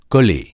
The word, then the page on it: coller: francais